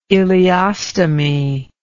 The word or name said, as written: ileostomy